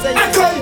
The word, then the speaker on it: Akon: Oui, il faut le prononcer aussi à la française, je veux dire. Oui. – Vit dans un pays de droit et de valeur. Akon que ça se prononce et c'est ça, Akon.